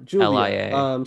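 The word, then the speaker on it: Giulia: Oh, it's Julia. – Julia. – But it's spelled like G-I-U. Yeah, Julia.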